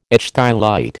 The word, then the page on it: ichthyolite: American English